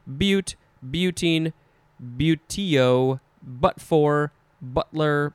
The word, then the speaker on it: butte: And then finally, we have the other word that is pronounced butte, but it is spelled B-U-T-T-E. Of course, all of us want to just say butt.